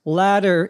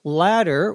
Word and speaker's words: latter: Latter is the second of two things. Now, don't confuse latter with another word that is pronounced exactly the same, which is L-A-D-D-E-R.